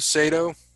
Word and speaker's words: Sato: Hikaru Sato, right? That's how you say it, right? – Sounds about right. – S A T O.